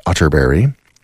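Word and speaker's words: Atterberg: It's spelled Otterberg, but pronounced Otterberry. I have it on good authority.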